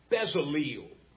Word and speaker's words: Bezaleel: I chose Bezaleel as the way I'd pronounce it.